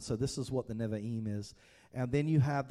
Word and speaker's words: Nevi'im: And maybe I got that right, the Nevi'im. I was told last week there are three syllables in it.